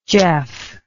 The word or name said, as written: jeff